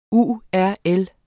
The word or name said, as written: URL